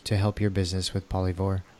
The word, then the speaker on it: Polyvore: And this is a platform that I actually had never come across before, but it's called Polyvore. Did I pronounce that right? Mm-hmm. Polyvore.